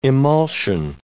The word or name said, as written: emulsion